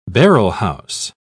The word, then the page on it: barrelhouse: British English